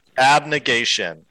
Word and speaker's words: abnegation: Abmitigation. How do you pronounce it? – Abnegation.